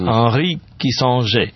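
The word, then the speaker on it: Henri Kissinger: Анри Киссинджер.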